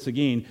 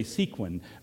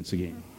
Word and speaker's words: Seguin: I mean, if you see the word Seguin, you are going to say Sequin, because that's just what your eyes will tell you it is, but that's, or say something else.